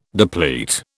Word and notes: deplete: British English

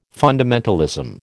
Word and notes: fundamentalism: American English

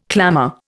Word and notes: clamour: Indian

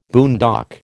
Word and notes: boondock: American English

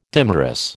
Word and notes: dimerous: American English

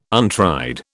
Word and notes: untried: British English

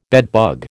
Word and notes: bedbug: American English

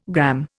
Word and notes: gramme: Indian

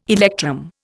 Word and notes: electrum: Indian

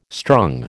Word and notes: strung: American English

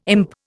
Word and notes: imp: Indian

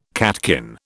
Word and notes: catkin: British English

